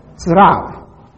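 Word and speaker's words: Sraf (צרף): The word translated flawless in the NIV is the Hebrew word sharaf. It's spelled T-S-A-R-A-P-S, Sraf. It means to smelt, to refine, or to test.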